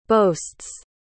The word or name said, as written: boasts